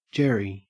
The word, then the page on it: jerry: Australian English